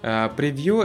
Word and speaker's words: Preview: Preview это, она получается как Pre и Vue, то есть вот так она читается, хотя как будто превью какой-то, предосмотр.